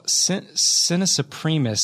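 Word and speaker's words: Sine Supremus: And it was, what was it, Sintus Supremus? – Sina Supremus.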